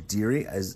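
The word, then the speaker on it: Dearie: The first one that I want to talk about is Long Daddy Green, which is a Blossom Deary.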